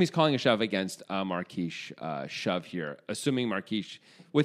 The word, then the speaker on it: Marques: Also, you know, you could just be like, I mean, I guess you're calling a shove against Pedro Marquez, I assume, no matter Marquise. – What. Marquise, excuse me. I spelled Marquez. I mean, no, it's spelled Marquise and Marquez. It's confusing. Anyway.